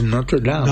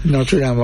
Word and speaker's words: Notre Dame: I think over there the way you pronounce it is Notre Dame. – Dame, yeah, Notre Dame. – Notre Dame over here.